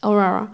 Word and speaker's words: Aurora: Aurora oder so. Kann das sein? War es richtig? Ja, richtig. Aurora.